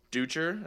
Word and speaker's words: Dutcher: Dutcher, that's how you say his name, Brian Dutcher. – Brian Dutcher.